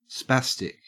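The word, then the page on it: spastic: au Australian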